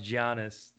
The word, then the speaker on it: Giannis: It's pronounced Giannis. – Thanks. – It's not. – You said it the right way.